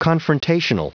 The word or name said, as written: confrontational